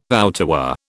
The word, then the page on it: outerwear: British English